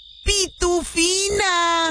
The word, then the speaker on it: Pitufina: Ay, mija, no se dice así. Se dice Pitufina.